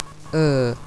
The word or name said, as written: ur